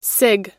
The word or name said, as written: cig